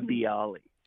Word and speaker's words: Bialy: Now, I want to ask you, because I don't know if I'm going to mispronounce this, but what is a Bialy? – A Bialy.